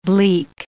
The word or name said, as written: bleak